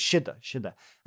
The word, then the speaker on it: sidhe: S-I-T-H, is peace, they're the same word. But long ago, do you remember, we used to spell that word she, S-I-D-H-E or S-I-D, so it's shidda.